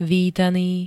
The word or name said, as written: vítaný